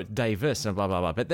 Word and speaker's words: Davies: And his name is spelt Davies, D-A-V-I-E-S, but it's always pronounced Davis.